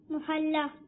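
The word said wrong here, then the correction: المعلیٰ: محلہ نہیں, آپ کچھ کا کچھ کر دیں گے اور کر رہے ہیں, صحیح ہے یہ نہیں ہے, محلہ نہیں ہے. عین لام.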